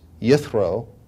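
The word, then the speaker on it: Yithro (יִתְרוֹ): Jethro is pronounced Yithro in Hebrew, and it means His Excellence.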